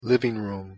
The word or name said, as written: living room